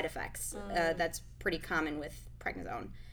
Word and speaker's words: prednisone: prednisone. Is that how you say it? – Yep. Yeah, steroid.